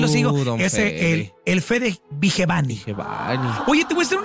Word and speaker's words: Vigevani: Entonces ya sabemos que es Fede Vigevani.